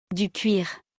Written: Du cuir

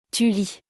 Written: tu lis

